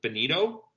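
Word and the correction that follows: Joel Bitonio: Joel Betonio. – Betonio, yes.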